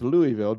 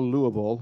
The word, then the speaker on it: Louisville: And it's pronounced Louisville. Don't go Louisville or whatever.